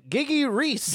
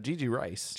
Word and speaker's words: Gigi Rice: Speaking of which, Kyle's pronunciation, Gigi Reese. – Gigi Reese. – Gigi Rice. – Gigi Rice played Veronica.